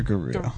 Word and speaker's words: Gerudo: Is it Gerudo or Gerudo? – Well, if you're going by Hyrule Warriors, it's Gerudo. That's how they say it in the...